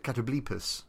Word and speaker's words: Catoblepas: Also related to the basilisk is the Catobel Pass, or Catobel Pass? – I've always pronounced it Catoblepas.